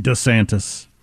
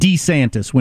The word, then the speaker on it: DeSantis: Anyway, he, during the Twitter thing on Thursday night, said DeSantis when he said his own name several times. So there was some talk among punditry that that's the way it's actually pronounced. – DeSantis.